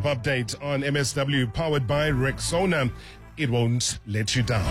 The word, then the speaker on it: Rexona: Now, first things first, it's Rexona. Just like Rexona. Yeah, say it right and say it proud.